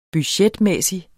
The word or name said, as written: budgetmæssig